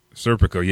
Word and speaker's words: Serpico: And I think they pronounce his name Serpico. So Serpico? – Serpico, yeah, that's right.